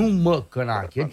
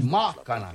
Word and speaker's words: Macanache: Deci nu Măcănache, ci Macanache. – Macanache. Da, dacă vrei să pronunți corect, Macanache.